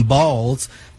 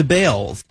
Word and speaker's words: Baals: After all, the family did change the pronunciation of their last name from Balls to Bales. – Yeah, you can't change that. – So it's the Harry Bales. – Illegal name change. – Spelled B-A-A-L-S.